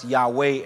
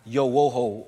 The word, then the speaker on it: Yahweh: So we really owe it to Theodoret of Cyprus to tell us that it should be pronounced Yahweh and not Yo-wo-ho or Ye-Ye-Wa.